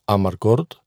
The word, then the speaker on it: Amarcord: Αν και η σωστή προφορά του αριστουργήματος του Φεντερίκο Φελίνη του 1973 είναι Amarcord, θα πούμε εδώ και σκέψεις για το Amarcord.